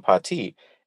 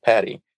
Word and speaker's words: Pati: this story that we're calling Pati. And so the core character is a Black American woman who, one, is mispronouncing her name. She's calling herself Patty. She's introducing herself as Patty.